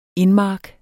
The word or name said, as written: indmark